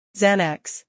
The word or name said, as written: Xanax